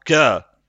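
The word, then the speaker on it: Kjær: Бо я намагався якось зрозуміти, як вимовляється людина, яку ми називаємо К'яєром або К'єром. Так, центральний захисник Мілана. Ну там же ж там кє.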